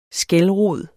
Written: skælrod

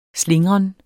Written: slingren

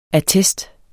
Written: ATTESTS

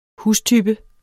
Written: hustype

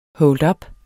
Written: holdup

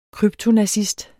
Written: kryptonazist

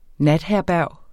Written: natherberg

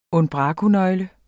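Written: unbrakonøgle

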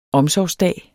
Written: omsorgsdag

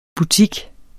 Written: butik

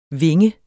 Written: vinge

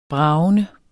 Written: bragende

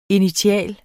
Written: initial

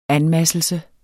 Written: anmasselse

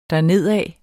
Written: dernedad

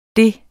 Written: det

